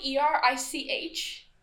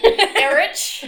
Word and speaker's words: Erich: It's spelled E R I C H. Mm-hmm. That's disgusting. Erich. His name is Erich.